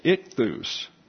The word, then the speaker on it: ichthus: And therefore, if he's showing me a fish here, what I call that is ichthus. Ichthus is the second syllable emphasis.